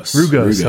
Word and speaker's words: rugose: Did I pronounce that one right? – Rugose.